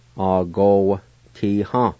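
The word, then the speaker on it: agowatiha: And most of the time, the A with the W would be silent, like this. A-go-ti-ha.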